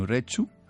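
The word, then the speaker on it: Urretxu: Nació en Urechu, ¿Así se dice? Sí, bien, bien.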